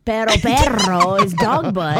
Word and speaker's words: pero: Perro with two R's is a dog. – Pero is how you say it, yeah. Pero or perro. – Pero. – Double R. – Yeah, if they really roll the R's, you got a dog.